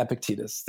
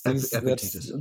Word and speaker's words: Epictetus: I don't know if I'm pronouncing it correctly, but Epictetus? – Yeah, Epictetus. Epictetus.